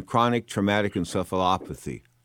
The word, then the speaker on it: chronic traumatic encephalopathy: And you know what's sort of scary about this is I did a show a few weeks ago on chronic traumatic encephalopathy.